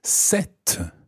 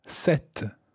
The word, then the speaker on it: cette: Now, the feminine version of this is cette. And it's spelled C-E-double-T-E, cette.